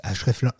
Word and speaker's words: Ahrefs: Href, Href non plus. Je vais dire Href langue, mais Href tout court. Ah, pour, Majestic SEO, CM SM, href, ça s'écrit A-H-R-E-F-S.